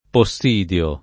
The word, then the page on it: Possidio: lingua italiana